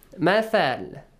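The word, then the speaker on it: مثل: مَثَلُ مَا يُنْفِقُونَ Mathal, tha, the pronunciation, it's important. Bite your tongue a bit. Like you say, three, I thought, I think, right?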